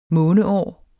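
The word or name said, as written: månesyge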